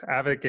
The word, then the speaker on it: Avik: It's Avik, A-V-I-K. It rhymes with havoc. That's how people always mispronounce it.